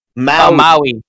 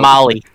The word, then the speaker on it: Maui: Maui. – Maui. – Okay. Not Molly. – Exactly. Popular club drug. – Like it's spelled M-A-U-I.